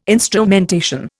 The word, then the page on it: instrumentation: Indian